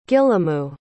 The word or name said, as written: gilamu